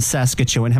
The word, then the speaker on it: Saskatchewan: Now, there's a little mini connection with Saskatchewan because we've got listeners from Saskatchewan. Have I said that correctly? – Well, if that's how you guys say it, then yeah, but we say Saskatchewan. But I can try and say it your way, man.